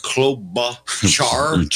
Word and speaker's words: Klobuchar: Klobuchar.